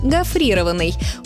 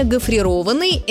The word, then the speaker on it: гофрированный: При этом, скорее всего, они поставят ударение на второй слог – гофрированный. Увы, этот вариант хоть и распространенный, но неправильный. Нормативное ударение «гофрированный» – это причастие образовано от глагола «гофрировать».